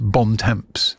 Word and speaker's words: Bon Temps: And I do need to make clear that they've requested specifically that it is pronounced Bon Temps. It's not my appalling French accent.